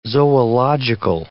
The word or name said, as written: zoological